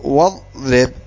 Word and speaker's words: واضرب: كأن تقول واضرب، فكأن فإنك لا تنطق بالهمزة فيصير نطقها هكذا واضرب.